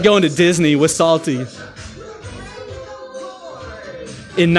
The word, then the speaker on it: Psalty: Yeah, don't get salty in here on me. No, that's not what I'm talking about. No, Salty was his name, spelled like the Psalms, right? P-S-A-L-T-Y. Salty, the singing songbook, right?